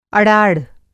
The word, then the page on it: अड़ाड़: Hindi